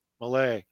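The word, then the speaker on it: Milei: So let's move on to Miley, how do you pronounce that guy's name? – Millay.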